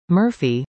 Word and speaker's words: MRFEE: Winter Winds Robotics, a Longmont startup company, product MRFEE, pronounced Murphy, was accepted into NASA's Small Business Innovation Research, SBIR, Phase 1 program for the 2022 fiscal year.